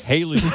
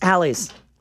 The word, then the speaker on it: Halley: Growing up, we were all told to pronounce it Halley's. And then as we got older and we learned more, it's Halley's. Fight me. I am 1,000% correct on this.